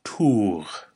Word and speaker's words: tugh: It's tour. Say that with me a couple times. Tour. Tour. That's spelled T-U-G-H.